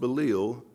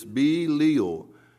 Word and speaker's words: Belial: And for all my life, I've mispronounced Belial. I listened to it twice, three times this morning. It's Belial, and I always pronounce it Belial.